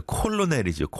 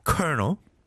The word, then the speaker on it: colonel: C-O-L-O-N-E-L. 이걸 누가 colonel이라고 생각하겠어요? 콜로넬이죠, 콜로넬. 그죠? But you pronounce it colonel. 그 다음에 또 이제 R과 L의 조합은 참, 아, 너무 힘들죠. rural. rural. 시골. R-U-R-A-L.